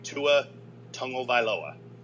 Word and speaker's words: Tua Tagovailoa: and let me say it right, slowly but correctly, Tua Tagovailoa could get some playing time,